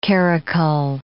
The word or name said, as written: karakul